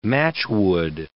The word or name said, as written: matchwood